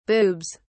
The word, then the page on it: boobs: British English